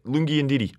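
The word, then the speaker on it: Lungi Ngidi: Lungi Ndidi, right? That's his name. – That's correct.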